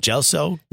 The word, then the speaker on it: Gelso: May 10th, T-E-N-F. – Come out to Gelso and Grand. – Gelso. Appropriately pronounced G-E-L-S-O and Grand on the corner of Mulberry and Grand Street.